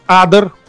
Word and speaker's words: Адер: А, его зовут Адер, ударение Адер. на А. Адер, прошу Адер. прощения. Ага. – Я общался с ним много лет и узнал, что его зовут Адер, а не Адер.